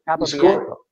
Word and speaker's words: Capobianco: So when I know how to say it, Capobianco.